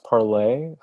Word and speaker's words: Parler: Yeah, I think it may actually be pronounced Parlay. – Oh yeah, they're sticklers. If nothing else, Q conspiracy theorists are sticklers for French pronunciation.